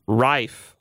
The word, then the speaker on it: Ralph: Rife.